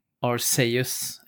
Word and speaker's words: Arceus: Alltså på japanska så säger man då Arceus är tydligen den korrekta japanska uttalet. Okay. – Okay. Arceus.